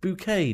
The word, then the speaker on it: Bucket: What flower, named for the lover of Apollo who it supposedly bloomed from, also gives its name to the bucket played by Patricia Routledge in Keeping Up Appearances? Although I'm told it's pronounced bouquet, darling.